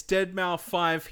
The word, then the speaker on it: Deadmau5: He said specifically in this thing, he said, hi, it's Deadmau5 here, and yes, that's how you pronounce it.